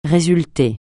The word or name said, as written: résulter